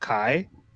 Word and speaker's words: Xi: It's funny because the one that's usually pronounced, I never knew how to pronounce it because I always thought it was Chinese, but it's XI. It's pronounced Kai. – Mm-hmm. – So I'm like, what? Isn't that Z?